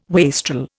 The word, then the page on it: wastrel: Indian